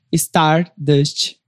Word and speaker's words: Stardust: Então, meu Instagram é arroba Chloe Stardust, chloe.stardust, C-H-L-O-E. Stardust, como se fala.